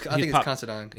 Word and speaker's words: Considine: But Paddy Considine, or Considine, I don't know how you say his name, but he's Considine.